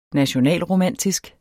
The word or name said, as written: nationalromantisk